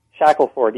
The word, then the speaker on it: Shackleford: Shackelford. Yes. – No relation to the famous explorer, is there? – No. He's Shackleton. Shackelford is an old English name.